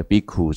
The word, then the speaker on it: Bhikkhu: 这个是梵本，Bhikshu 啊，比丘比较像是俗语或巴利语，Bhikkhu 那个丘啊，闽南话念什么？你那个丘先生怎么念？哭嘛，对，Bhikkhu 其实是很准的啊，大家听得懂，因为那个山丘的丘跟那个姓氏的丘应该。在比较古代的发音是同一个音的啊、哦、,KK 啦齁、哦。